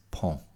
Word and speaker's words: pont: And of course, the word, I had it in my head and I dared not say it, but it's pont, P-O-N-T, pont, pont d'Avignon.